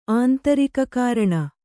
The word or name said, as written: ಆಂತರಿಕಕಾರಣ